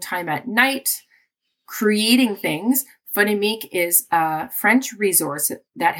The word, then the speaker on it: Phonémique: Phonémique. Did I say it right? – Phonémique.